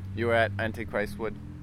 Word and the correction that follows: Anarchistwood: Is that how you say it? Antichristwood? – No, Anarchistwood.